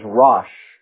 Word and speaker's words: rosh: The word chiefs in the Hebrew is rosh. We would spell it, if we put it in English characters, R-O-S-H, rosh.